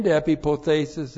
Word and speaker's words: epipothesis: E-P-I-P-O-T-H, long E-S-I-S. Epipothesis.